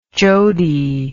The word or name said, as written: jodi